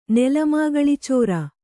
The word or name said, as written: ನೆಲಮಾಗಳಿಚೋರ